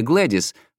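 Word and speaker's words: Gwladys: Ее имя Глэдис, но пишется Гвледис. – В смысле, с буквой «Г»? – Да, с «Г» и «В».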